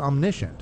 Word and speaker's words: omniscient: So another quality of God, the Father, and I mentioned it, I said it wrongly before, but it's omniscient.